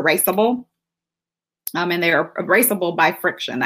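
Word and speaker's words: FriXion: And you can only use, and I've been pronouncing this so wrong, I've been calling it Frisson, but it's actually Friction.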